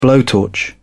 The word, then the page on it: blowtorch: British English